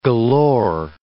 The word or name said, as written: galore